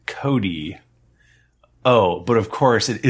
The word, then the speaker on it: Chode: This is my beautiful baby dog Chode. Pronounce Cody, by the way. – Cody, C-H-O-D-E, Cody.